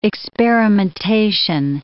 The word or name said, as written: experimentation